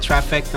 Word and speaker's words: Trifeckta: If that's something that you're interested in doing, at your own risk, you can find me at Trifeckta on just about everything except for Facebook.